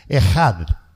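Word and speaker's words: Ejad (אחד): Hay dos palabras para decir uno en hebreo, pero aquí se usa una palabra que se pronuncia Ejad.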